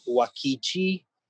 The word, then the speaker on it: Uakti: is a Brazilian group called Wakichi, which is spelled U-A-K-T-I.